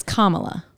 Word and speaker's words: Kamala: Because so many people, I don't know, I can never remember how you're supposed to pronounce it. – It's Kamala.